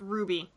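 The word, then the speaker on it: RWBY: RBWY, is that? – Ruby. – Kind of like that. – Is that what it's called? – It's just pronounced Ruby.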